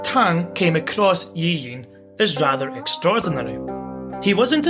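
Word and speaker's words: Yi Yin: And his name is Yi Ying, and I'll say it again, just because when I pronounce it, it does sound like I'm saying one name, but it's actually two. Yi Ying.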